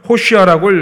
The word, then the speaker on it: 호시아: 여기서 도우소서를 히브리어 원어로 읽으면은 호시아라고 읽습니다.